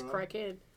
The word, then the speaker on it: Kraken: It's Kraken.